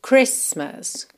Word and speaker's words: Christmas: é o fato de que nós não pronunciamos o T na palavra Christmas. Isso é algo assim, um bocado fora do comum, né? Não é um padrão. Geralmente, como eu sempre digo para vocês, as consoantes oclusivas, como T, D, podem ser pronunciadas mais, menos ou nada, né? Podem aparecer mais, menos ou nada. Então, seguindo a regra, eu poderia dizer Christmas, Christmas or Christmas, right? Só que, com essa palavra aqui, especialmente, a gente realmente não pronuncia a letra T.